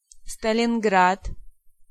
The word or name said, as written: Stalingrad